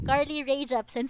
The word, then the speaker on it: Carly Rae Jepsen: Tapos yung next is kay, is kay Carly J. Rap, Car, wow, Carly Rae Jepsen.